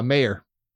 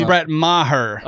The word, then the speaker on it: Maher: Mayer. – Nope. – He's up there. – It's not how you say his name. – It's not, though. It's Brett Maher.